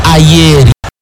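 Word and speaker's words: Ajer: Haya, kwenda kucheza juu, hata Ayeri inaandikwa Ajeri, lakini inatamkwa Ayeri.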